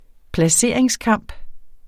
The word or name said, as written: placeringskamp